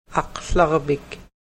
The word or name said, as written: aklarvik